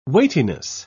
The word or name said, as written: weightiness